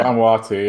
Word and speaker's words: Vanuatu: Is it Vanuatu? – Yeah. – Vanuatu, yeah. – They have two teams in this tournament. Vanuatu and Vanuatu B, and I don't really know why. But Vanuatu B got all the way to the final and got beat by Papua New Guinea.